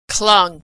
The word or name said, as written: clung